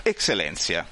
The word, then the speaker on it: Eccellenza: Responsabile comunicazione, e qui mi aiuti a capire come, ecco, si pronuncia. – Eccellenza. – Alla latina. – Alla latina, esatto.